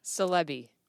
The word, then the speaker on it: Silebi: Silebi. S-I-L-E-B-I. – Correct. – Okay. Where did salami come from? – So I got the call sign salami when I was flying T-2s in Meridian, Mississippi. One of the rites of passage in naval aviation is that your squadron comes up with your call sign. So my last name is Silebi, and there's no other meaning for salami other than it rhymes with my last name, although it's usually a good topic of conversation.